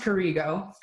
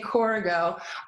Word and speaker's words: Corrigo: So Corrigo it's pronounced Corrigo for the record. I know a lot of people say Corrigo.